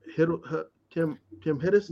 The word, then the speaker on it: Hiddleston: Let's talk about Tim Hiddleston.